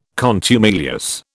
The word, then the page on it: contumelious: British English